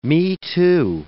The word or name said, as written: me-too